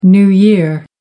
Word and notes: new year: American English

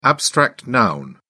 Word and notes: abstract noun: British English

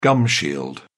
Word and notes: gumshield: British English

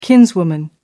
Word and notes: kinswoman: American English

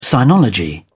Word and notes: sinology: British English

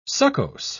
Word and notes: succose: British English

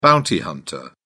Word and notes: bounty hunter: British English

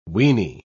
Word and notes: weenie: British English

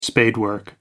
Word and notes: spadework: American English

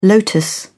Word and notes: lotus: British English

